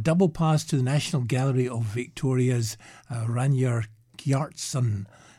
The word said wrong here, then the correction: Ragnar Kjartansson: Pronunciation's right next to the word. – Oh, Ragnar Kjartansson.